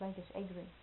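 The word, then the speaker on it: Lajos Egri: Legas, I think it's pronounced Legas Agri, wrote a book on writing, playwriting primarily, conflict.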